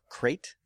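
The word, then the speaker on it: Crait: It's C-R-A-I-T, but I assume it's pronounced crate. That's just rate, and the C is silent.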